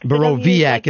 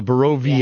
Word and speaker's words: Borowiak: So, say it for me one time. I'm just going to start practicing it. Borowiak? – Borowiak. – Borowiak. It's a V. Yeah. I gotcha. So, Borowiak.